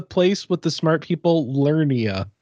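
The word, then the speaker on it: Liurnia: It is Lernia. Yeah. It's spelled weird, but we'll just say Lernia.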